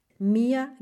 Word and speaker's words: mir: Mia gefällt. Este este es el dativo, se escribe M-I-R, es el dativo como igual que en español.